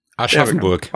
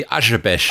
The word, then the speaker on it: Aschaffenburg: How do you say that? – Aschaffenburg. – Wow, that's close. – Or you can say the Hessian way would be Aschebesch.